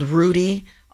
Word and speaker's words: Rude: And I want to talk about briefly, within NOAA, there is this very special ship called Rudy, R-U-D-E, but pronounced Rudy.